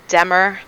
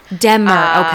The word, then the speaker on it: DMER: And first of all, am I saying that correctly or do you say DMER? – So I call it Demer. – Demer, okay.